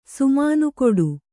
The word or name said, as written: ಸುಮಾನುಕೊಡು